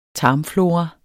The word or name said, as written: tarmflora